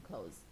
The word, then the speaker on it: clothes: 注意啊，那个 clothes 也可以读成 clothes，就是就像我们说关门那个 close 一样，读音是类似的啊，注意一下 es 结尾，可以读成 close，也可以读成 clothes。